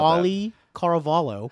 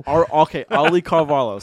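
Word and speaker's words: Auli'i Cravalho: Aiello Cavallo. I forgot the pronunciation of that already. I'm so sorry if I butchered that. 2016, tell us about Ollie Caravallo. – Ollie Caravallo, sorry.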